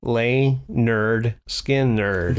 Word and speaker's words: Lynyrd Skynyrd: Probably because Skynyrd is S-K-Y, so you might think of Sky.